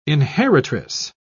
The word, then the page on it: inheritress: British English